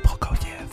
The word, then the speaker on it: Prokofiev: Provokiev, Prokofiev, mon Dieu, ça se prononce mal, ou Beethoven? C'est Prokofiev.